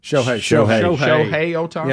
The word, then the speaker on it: Shohei Ohtani: Do you say his name Shohei Ohtani? – Shohei Ohtani.